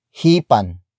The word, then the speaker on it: hipan: Word for word it's hipan, ninyo, ang, mga, kandila, hipan, ninyo, ang, mga, kandila hipan ninyo ang mga kandila.